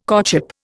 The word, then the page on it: courtship: Indian